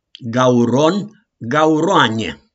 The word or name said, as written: GAURON